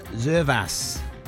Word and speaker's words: Zervas: Name of the song is Roxanne by Arizona Zervas. That's how you say his name.